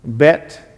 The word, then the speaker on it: Bet: But here you have Bet David. What is Bet? Or we say Beth. The Jews don't pronounce the H sound.